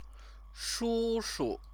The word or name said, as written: shushu